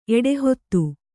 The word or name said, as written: ಎಡಹೊತ್ತು